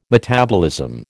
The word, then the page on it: metabolism: American English